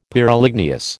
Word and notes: Pyroligneous: American English

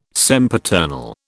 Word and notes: sempiternal: British English

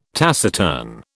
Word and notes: taciturn: British English

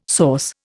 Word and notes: source: Indian

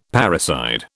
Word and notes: parricide: British English

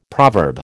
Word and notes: proverb: American English

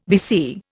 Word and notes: VC: Indian

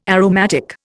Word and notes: aromatic: Indian

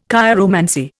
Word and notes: chiromancy: Indian

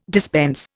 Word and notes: dispense: Indian

